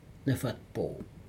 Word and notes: nefatpo: Aneityum

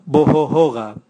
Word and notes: bohohoga: Raga

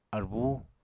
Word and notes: arbú: Apatani

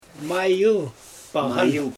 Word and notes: majɯl paɣan: Xyzyl

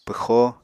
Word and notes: pwxo: Apatani

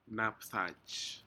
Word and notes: napdaj: Aneityum

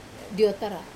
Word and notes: otara: wamesa

